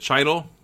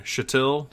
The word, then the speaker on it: Chytil: And then, is it Chytil or Chytil? I don't know how they say his name. – Dude, I've heard so many. – Chytil? Don Chytil? – Chytil. – I heard Chytil. I heard Chytil on the broadcast for sure. – Apparently the C is silent, so it's just Chytil.